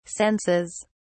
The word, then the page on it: senses: British English